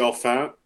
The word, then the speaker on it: Olyphant: I guess we should go in chronological order, but I just want to start out by saying that Mickey is Timothy Olyphant.